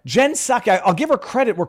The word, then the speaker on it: Psaki: I will give Jen Psaki. No, I should say that. I initially was actually pronouncing her name Psaki, and then I was told it's a silent P.